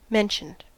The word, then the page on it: mentioned: American English